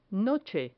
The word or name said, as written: noche